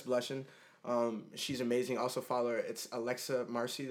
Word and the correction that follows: Macri: That's also, or Macri, sorry, I'm saying it wrong.